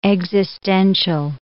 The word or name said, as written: existential